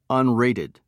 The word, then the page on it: unrated: American English